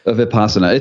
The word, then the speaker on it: Vipassana: Vipassana.